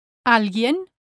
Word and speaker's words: alguien: Rule number two says, the letter U is silent in the cluster G-U-I, gi. Here are two examples. Alguien, somebody, alguien, and la guía, the guide, la guía.